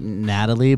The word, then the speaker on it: Nathalie: I never know how to say her name because it's got an H in it. So it's like Natalie, but it's with an H.